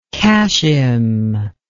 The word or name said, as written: kashim